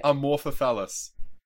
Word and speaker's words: Amorphophallus: Did I say it right? – Amorphophallus. Yeah.